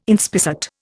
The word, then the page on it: inspissate: Indian